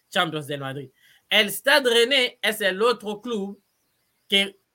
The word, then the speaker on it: Reims: Llega a la primera división, ficha para el Stade René que muchos dicen Reims. Por eso, por eso, por, eso yo digo que en los clubes de Francia hay que leer cómo se escribe porque es muy difícil.